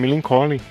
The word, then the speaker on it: Millencolin: fãzão de Millencolin, mas ele chamava de Millencolin. E eu não, bicho, é Millencolin.